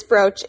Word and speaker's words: brooch: Well, first of all, it's brooch, not brooch. – Brooch? – That's right.